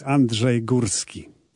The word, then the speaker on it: Andrzej Górski: Well, let's first start getting things correct, because I know that you've Americanized your name, Andrew Gorski, for me. But just give everyone a chance to hear it how it should be pronounced. – Yes, I did. I just wanted to make your life a little bit easier. – And you are. – My true name is Andrzej Górski.